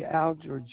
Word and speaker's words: Alger: Alger, Jr. Last name is A-L-G-E-R, two syllables.